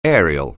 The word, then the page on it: aerial: anglais